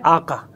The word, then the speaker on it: آقا: یہ ایسا نہیں ہے، یہ ہے آقا. لیکن کیونکہ ان کا زبان کا جو گلے کا جو تلفظ وہ نکلتا ہے آغا، تو کہتے ہیں آغا تشریف لے، آغا بیٹھیے، وہ لفظ ہے آقا.